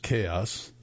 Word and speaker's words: KHXO5: The local act KHXO5, pronounced Chaos, is planning a characteristically show-stopping show.